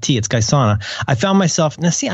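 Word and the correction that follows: Guayusa: Gayusa, that's it. God damn it. Why do I say that wrong? Gayusa.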